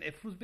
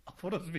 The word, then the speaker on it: Efruz: İstanbul kadınlarının çoğu sizin adınızı telaffuz edemiyor. Sizden bahsederken Efruz Bey yerine Aforoz Bey diyorlar.